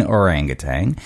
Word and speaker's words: orangutan: I have it written in front of me as orangutan, but I said orangutan.